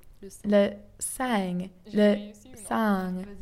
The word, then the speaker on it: sang: Blood is le sang in French, but I can never manage that pest of a nasal vowel right.